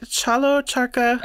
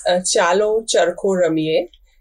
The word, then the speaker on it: Chalo Charkho Ramiye: Chalo charkha? – It is chalo charkho ramiye. It is a Gujarati term.